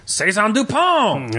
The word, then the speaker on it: Saison Dupont: Saison Dupont!